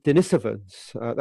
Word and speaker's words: Denisovans: And then in 2010, the Denisovans, that's the correct pronunciation,